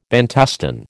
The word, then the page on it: Bantustan: American English